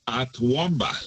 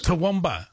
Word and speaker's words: Toowoomba: Is that the right way to pronounce it? – Toowoomba. – Toowoomba. I was all over the place. I wasn't even close. I didn't even get close to the pin.